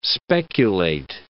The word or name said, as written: speculate